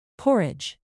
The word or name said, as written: porridge